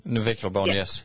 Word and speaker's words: navicular: So now Bucky's got this broken navicular bone. Am I saying that right? Navicular bone? – Navicular bone, yes.